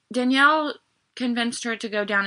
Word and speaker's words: Dynel: Okay, so she went to the home of, I think it's Danielle. It's spelled weird. It's D-Y-N-E-L. – Mm-hmm. – Danielle Lane.